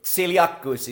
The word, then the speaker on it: Zilliacus: That was pretty good. Zilliacus is the way I would say it, but close enough.